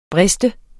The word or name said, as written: brist